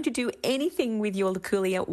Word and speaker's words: Luculia: Luculia tree.